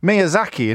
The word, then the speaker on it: Miyazaki: Our Dean is in Miyazaki in Japan. Crikey. Question or answer, Dean? – It's an answer, thank you. Well pronounced, by the way. – Thank you very much. It's quite phonetic, isn't it, I think?